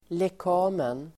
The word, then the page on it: lekamen: [le:k'a:men]